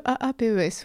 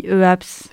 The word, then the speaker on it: EAPS: On disait le AAPES, voilà, parce qu'on trouvait que ça, faisait... ça sonnait bien. Mais en fait, non, c'est pas du tout comme ça qu'il faut prononcer. – Nous, on dit EAPS.